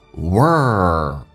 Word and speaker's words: world: 每个发音打开口腔一点点 waxing when the rest of 有一个零 rest of the world 这个单词很难发，美式发音重度卷舌卷到极限，再往前推靠向你的硬腭，叫 world world